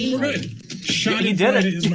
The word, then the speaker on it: Schadenfreude: Is that really how you pronounce that? Every time somebody says that word on the show, we ask, is that how you pronounce that? – Schadenfreude. That is how you pronounce it.